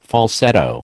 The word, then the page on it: falsetto: American English